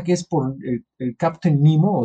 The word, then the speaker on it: Nemo: o sea, Nemo él lo tomó del latín, eso sí es así, se, como se escribe, así se lee Nemo, que, pues significa ninguno o, nadie.